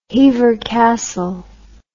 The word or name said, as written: hever castle